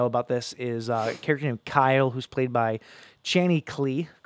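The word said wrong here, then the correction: Kley: No, it's K-L-E-Y. It's Clee.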